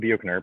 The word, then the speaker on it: Buechner: Okay, it's by Frederick Buechner, spelled Buechner, but Frederick Buechner is how it's pronounced.